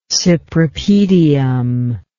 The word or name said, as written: cypripedium